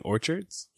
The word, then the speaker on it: orchards: Did I say that right? Orchids? – Orchids? – Orchids? – Wait, are you saying orchards? – Yes.